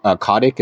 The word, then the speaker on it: Kotick: but Bobby Kotick, is that how you pronounce it? – Yep.